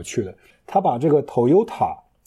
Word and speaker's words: Toyota: 如果是 T O Y O T A 呢，就更容易会读成 Toyota，它可能稍微清楚一点。